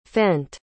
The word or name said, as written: fent